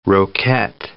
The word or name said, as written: roquette